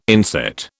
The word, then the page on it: inset: British English